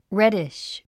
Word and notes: reddish: rédiʃ; レディッシュ

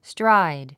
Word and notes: stride: stráid; ストライド

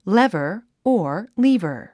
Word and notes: lever: lévə r; レバァ

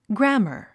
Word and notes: grammar: grǽmə r; グラァマァ

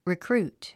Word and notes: recruit: rikrúːt; リィクルート

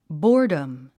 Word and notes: boredom: bɔ’ː r dəm; ボーダム

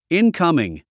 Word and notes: incoming: ínkʌ̀miŋ; インカミン

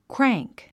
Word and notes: crank: krǽŋk; クラァンク

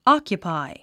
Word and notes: occupy: ɑ’kjupài; オキュパイ

